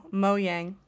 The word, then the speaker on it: Mojang: No, but how do you pronounce the developer of Minecraft? – Oh, Mojang. – You say Yang, okay.